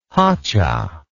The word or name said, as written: hotcha